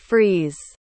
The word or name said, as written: friese